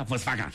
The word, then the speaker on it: Volkswagen: Volkswagen, se pronuncia Volkswagen.